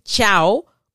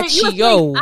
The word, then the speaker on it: ciao: actual way the chow word is spelled is C I A O yeah. rather than C H I A O, but that's how they pronounce it. Wait, so I used to say were, achio. Achio. – Yes. You were saying achio. With the k sound in the middle, yes.